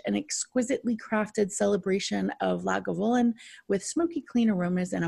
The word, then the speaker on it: Lagavulin: So it is pronounced Lagavulin.